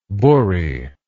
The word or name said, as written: buri